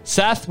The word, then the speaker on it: saith: We are starting a brand new series on our small groups podcast series called Thus Saith the Lord, and it is pronounced Seth.